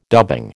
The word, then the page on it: dubbing: American English